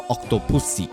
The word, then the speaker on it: Octopussy: Now, during the week, we had a special event here in Sydney to celebrate the 40th anniversary of Octopussy, or Octopussy, as you will hear it be pronounced a little bit in this episode, where a screening was held in Sydney to celebrate the occasion.